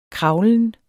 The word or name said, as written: kravlen